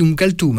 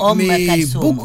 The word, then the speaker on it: Oum Kalthoum: Alors, nous, on dit Oum Kalthoum. Hein, alors, toi, T-H-O-U ou Oum Kalthoum. – Beaucoup prononcent avec le T maintenant.